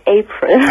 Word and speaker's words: April: Ich heiße nicht April, ich heiße April. – Ach, April. Entschuldige bitte. April. Aber das heißt ja auch April, oder? – Ja, genau, aber wird Englisch ausgesprochen.